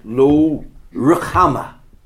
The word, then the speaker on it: Lo-Ruhamah: That's how you pronounce it, Lo-Ruhamah.